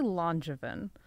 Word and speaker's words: Langevin: But then I remember you saying that it's like half the French one and then half the like American one, pronouncing it literally. – How do you pronounce it actually? – I say Langevin.